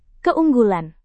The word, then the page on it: keunggulan: indonesian